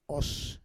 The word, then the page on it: os: Roon